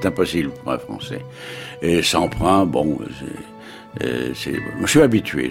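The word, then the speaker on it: Semprun: Nous allons prononcer à la française le nom de Semprin, c'est plus sûr.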